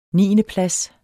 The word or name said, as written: niendeplads